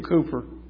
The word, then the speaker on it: Cowper: William Cooper, one of the great hymn writers of history. Spelled C-O-W-P-E-R, but I understand it's pronounced Cooper.